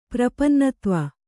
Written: ಪ್ರಪನ್ನತ್ವ

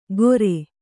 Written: ಗೋರೆ

